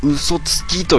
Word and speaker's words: 嘘吐き: 194、嘘吐きと書いて嘘吐きと読まずに嘘つきと読むんじゃよ、と。